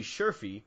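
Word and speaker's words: Sherfy: You have Archie Bradley, Andrew Chafin, Brad Boxberger now, Jimmy Schaafly. I completely butchered that. Jimmy Scherfie, a couple others, but yeah, that sounds so much better.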